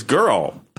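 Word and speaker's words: Jørrel: So when I was in Norway this past year, I met somebody, and her name was Girl, and it's spelled J with an O and a slash the O, R R E L. Girl.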